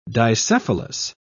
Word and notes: dicephalous: British English

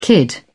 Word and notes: kid: British English

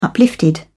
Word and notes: uplifted: British English

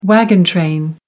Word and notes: wagon train: American English

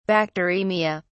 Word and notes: bacteraemia: British English

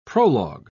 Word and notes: prolog: British English

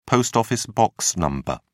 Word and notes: post-office box number: British English

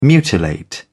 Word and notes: mutilate: British English

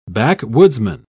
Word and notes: backwoodsman: British English

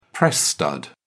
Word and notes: press stud: British English

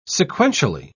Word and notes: sequentially: British English

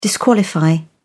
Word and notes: disqualify: British English

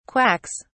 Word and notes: quacks: British English

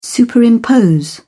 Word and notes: superimpose: British English

